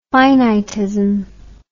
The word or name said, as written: finitism